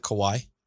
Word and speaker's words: Kauai: is that Kauai? – Yes, sir. – Good. I said it right.